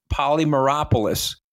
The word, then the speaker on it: Polymeropoulos: And joining us now on Open Book is Marc Polymeropoulos. And Marc, I got to tell you, I am so happy I pronounced the name right, because, you know, I'm an Italian kid from Long Island and I can butcher people's last names.